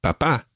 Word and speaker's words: papá: And papá. – Papá. – Now, just one other wee thing. We mentioned accents already. Mamá and papá both have accents on the last syllable.